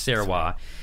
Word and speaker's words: Sarawak: And so they're, the other island has the Sarawak, and it's written Sarawak, but it's pronounced Sarawak.